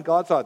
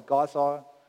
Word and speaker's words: Gaza: Also wir sagen jetzt immer entsprechend den Nachrichten und der englischen Aussprache Gaza. Früher haben wir Gaza gesagt, weil es so in der Bibel steht und weil es auch im Hebräischen so ähnlich ausgesprochen wird.